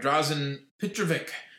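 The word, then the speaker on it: Dražen Petrović: But Drazen Petrovic, as I'm learning, is more the proper pronunciation as I think it's gone by.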